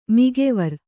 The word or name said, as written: ಮಿಗೆವರಿ